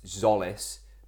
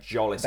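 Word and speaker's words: Tzolis: for the audio listeners, I know it's Zolis, but I will continue to pronounce his name Jolis until he actually looks like a footballer.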